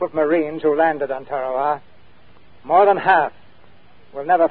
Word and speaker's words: Tarawa: Ladies and gentlemen, there's a tiny island in the mid-Pacific called Tarawa. That pronunciation, incidentally, is from the National Geographic Society.